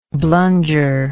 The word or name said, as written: blunger